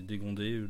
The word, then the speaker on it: dégondé: enfin, voilà, c'est dégondé, dégon... dégongé, je ne sais même pas comment on prononce. C'est même dégondé. Pas s'ils disent. – Dégondé, si, ok.